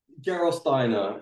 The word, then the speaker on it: Gerolsteiner: Gerolsteiner.